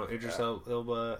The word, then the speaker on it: Idris Elba: And Aldris Elba that's how you pronounce his name, right? – I don't know. Idris Elba.